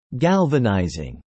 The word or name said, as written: galvanizing